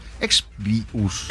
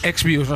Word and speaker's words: Exvius: tú lo pronuncias mejor que yo, Final Fantasy Brave Exvius. Exvius, no sabía.